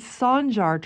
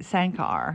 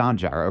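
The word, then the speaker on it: Sancar: It's Sanjar Turkish Cultural Center. I was calling it Sankar.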